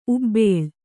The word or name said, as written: ಉಬ್ಬಾಳು